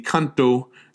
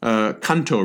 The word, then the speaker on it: Kanto: Kwantung, K-W-A-N-T-U-N-G. The orthography of this name is kind of strange. What it is a Japanese pronunciation of the Chinese term for the general region. The Chinese pronunciation is Guangdong, east of the pass. East of the pass. Guangdong. And then the Japanese pronunciation of that is the Kanto, Kanto region.